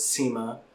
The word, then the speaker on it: Cima: The main event had, which is one of the, a couple of wrestlers that were coming over, was Cima. – Okay. – Which is spelled C-I-M-A.